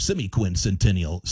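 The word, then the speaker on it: semiquincentennial: It's our semi-quincentennial celebration. That's the way to pronounce that.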